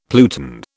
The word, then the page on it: pluton: British English